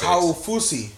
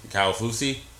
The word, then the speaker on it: Kaufusi: And then third round they got Bronson Kaufusi, think I said that right, but he's like what the NFL is looking for when you're like six. six. – Kyle Fusi. – Kyle Fusi.